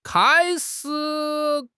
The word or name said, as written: kai1 shi3